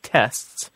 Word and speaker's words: tests: And in the case of tests, it's spelled T-E-S-T-S, right? T-E-S-T-S. – Right. – Let's just talk about speaking for a second. You actually, most of us, almost never pronounce the T.